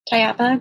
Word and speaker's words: Tayaba: They didn't know how to pronounce my name, Tayaba. And I told them, you know, one way to remember my name is that it rhymes with the Spanish word guayaba, Tayaba guayaba.